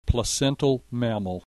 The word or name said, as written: placental mammal